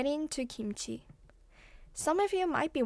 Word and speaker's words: 김치: Oh, by the way, it sounds like kimchi if you pronounce the word in English, but I'll be pronouncing the word in Korean, so see if you can notice the difference.